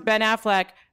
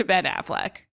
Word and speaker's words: Ben Affleck: The best part was when he pronounced Ben Affleck, Ben Affleck, then caught himself, then apologized to Ben Affleck.